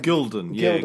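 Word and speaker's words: Gulden: Now, I heard several different pronunciations, but I heard gold gilden. Do you have something for what the gold coins were called, the currency? – I have gilden.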